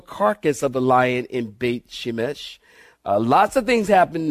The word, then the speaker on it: Beit Shemesh: And they don't pronounce it Beth like it's written here. They pronounce it Beit. Beit. Beit Shemesh.